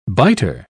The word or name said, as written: biter